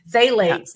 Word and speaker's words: phthalates: Yeah, you know, I totally agree, because especially the smell good, it has a phthalate, which is what it's pronounced as, starts with a P, but phthalates.